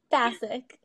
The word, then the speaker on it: Basik: Basik. – Basik, not basic. Basic.